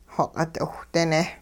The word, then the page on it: xoq'it-'iwhdine: hupa